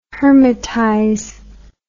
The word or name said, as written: hermitize